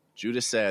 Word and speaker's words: Giudice: And something else that I've read recently about, and Mei Wen Ho's work and Emilio Del Giudice, I think is how you say it. – Yep.